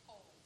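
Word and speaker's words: Cobh: Am I saying it Cobb or Cobb? Is it Cobb? – They actually said that it's pronounced Cove, like C-O-Cove.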